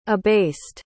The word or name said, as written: abased